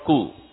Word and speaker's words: coup: That's called coup. C-O-U-P. – C-O-U-P. Coup under. And in Africa, they say that they are going to do it. P is silent. Coup.